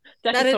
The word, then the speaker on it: Decatur: Well, so I thought for a really long time, because I'd never heard anyone say Decatur out loud, that it was like some French influence part of Georgia and that it was Decatur. And so that's how I personally refer to it. But I have been reminded many times that it is not pronounced that way.